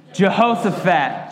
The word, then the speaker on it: Jehoshaphat: Jehoshaphat.